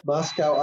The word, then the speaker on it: Moscow: Moscow, Idaho is pronounced to rhyme, the locals say, with Costco, to make it sound like Moscow, Russia.